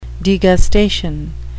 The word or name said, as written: degustation